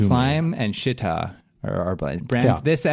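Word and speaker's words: Schiit: And they say it's pronounced Shitta. – Shitta. – Which does not. – Shitta. – Does not, it's a proud German name.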